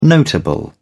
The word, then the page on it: notable: British English